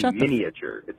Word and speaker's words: miniature: I know it's spelled that way, but the A is silent. You don't say miniature.